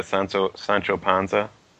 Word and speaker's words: Sancho Panza: And then Garcia Lopez loves Don Quixote, so in a previous con, he drew Don Quixote, and then he drew, I can't pronounce the heavy guy's name, Sancho Panza or whatever. I don't know. – That was close. I think it, yeah, Sancho Panza.